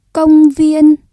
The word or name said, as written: công viên